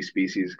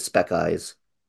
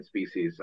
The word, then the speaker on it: Species: And, my only memory of Species is seeing it the videotape on my uncle's shelf and him laughing at me because I pronounced it speck eyes. – I mean, I actually remember when I came back from seeing Species, I talked to Matt on the phone and he was like, "Did you see Species?"